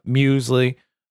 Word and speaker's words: Muesli: Some of the finer details with like Alec and Muesli, and that's how you pronounce it. I looked it up, Muesli.